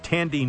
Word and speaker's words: Thandie: Actress Thandi, it's actually spelled Thandi, but it's pronounced Tandy Newton.